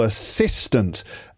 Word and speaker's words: assistant: allows users to interact with a virtual assistant. Assistant, um, the stress should be on the second syllable, but it's stressed the first and third syllables and other things.